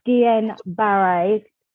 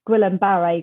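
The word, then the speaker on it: Guillain-Barré: Uh, yeah, so I believe it's pronounced Guillain Barre. It's French, but I've always said Guillain Barre because it just sounds easier in my accent.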